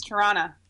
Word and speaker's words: Toronto: It's Toronto. – Toronto. – Toronto. – In the middle of Toronto. – There you go. You got it. Nobody awesome. Pronounces the second T. Nobody pronounces the second T.